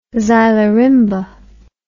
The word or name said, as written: xylorimba